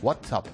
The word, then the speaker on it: WhatsApp: WhatsApp, ¿no? Se dice así.